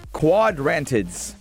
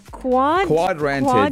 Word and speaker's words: Quadrantids: Quadrantids. – Quadrantids.